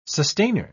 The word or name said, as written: sustainer